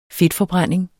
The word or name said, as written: fedtforbrænding